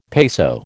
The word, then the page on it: peso: American English